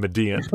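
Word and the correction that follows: Medellín: I think it's Medellin. Is that correct? – Yes, it's Medellin for Spanish speakers, and then for people from Colombia, it's Medellin, which is even harder from a white guy from Chicago.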